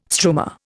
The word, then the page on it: struma: Indian